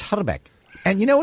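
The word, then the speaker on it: Hrbek: I speak a little bit of Czech, and the actual correct pronunciation of his name is Herbeck. You got to roll the eye.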